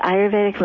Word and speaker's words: Ayurvedic: Now, describe for me, and correct my pronunciation if it's wrong, is it Ayurvedic medicine? – Yes, Ayurvedic medicine, that's exactly it.